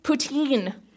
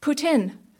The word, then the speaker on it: poutine: They serve it in other places in North America, but they always pronounce it wrong. They say poutine, when really it's more like poutine, but French-sounding.